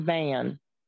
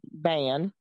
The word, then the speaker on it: Bahan: B-A-H-A-N, Bahan or something like that. Also Irish. Oh, pronounced Ban. She said she typed slow. No, you're fine, Sandra, if you can hear us. But yeah, so Ban, pronounced Ban, B-A-H-A-N, and it's pronounced Ban.